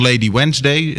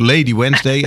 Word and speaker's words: Lady Wednesday: Nu aan de lijn eh, Petra van Den Kieboom ehm, en ik, eh, van Lady Wednesday. Zeg ik dat goed? – Ja, dat zeg je helemaal goed. – Ja, dat zeg ik helemaal goed. Lady Wednesday.